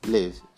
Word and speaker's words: live: And this live is spelled as L-I-V-E. So I live in Shanghai. And this is the short E sound. So I live in Shanghai. Now the problem is, this word is usually confused with another word which has the long E sound, leave.